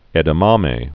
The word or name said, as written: edamame